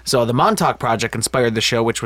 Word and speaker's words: Montauk: So did you know that Stranger Things, the show was originally called Montauk? I'm pretty sure that's how you pronounce it. M-O-N-T-A-U-K, right? – Mm-hmm.